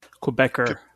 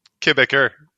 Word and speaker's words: Quebecer: Quebecian? How do you say that in English? – Quebecer. – Quebecer.